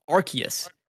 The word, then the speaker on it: Arceus: Our nominees are Elden Ring, Live a Live, Pokemon Legends, I think it's pronounced Arceus. – Yeah. – Even though my head wants to say Arceus always.